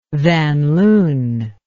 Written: Van Loon

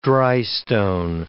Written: drystone

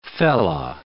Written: fella

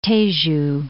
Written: Tejo

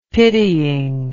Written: pitying

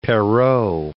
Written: Perrault

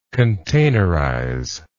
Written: containerize

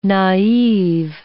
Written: naive

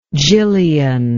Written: Gillian

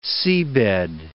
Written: seabed

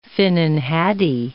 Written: finnan haddie

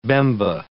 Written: Bemba

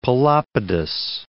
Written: Pelopidas

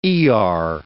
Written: Iyar

